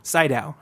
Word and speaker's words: Sydow: Max von how do you say it? – Sydow, that's right.